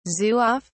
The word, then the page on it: zouave: British English